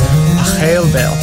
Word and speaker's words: Pachelbel: So we started doing the piece and I thought, this is a lot like Pachelbel. So actually, if you want to know the real pronunciation of his name, it's Pachelbel.